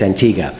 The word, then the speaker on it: Antigua: Antigua, or is it Antigua? Locals say it's Antigua.